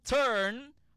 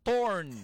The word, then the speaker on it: turn: Turn, T-U-R-N, hindi nga torn. Ang torn nga T-O-R-N, bali na yung tulaan mo, torn. Gani, torn between two lovers. Feeling like a fool. Loving both of you. Amo na yung torn, T-O-R-N. Pero kung turn, T-U-R-N.